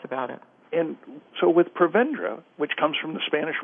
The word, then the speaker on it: Prevendra: Prevendra oh, comes and from the Spanish root word.